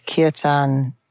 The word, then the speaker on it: Kirtan: Talk to me for a moment about the Learn to Lead Kirtan, I think you pronounce it. – Kirtan.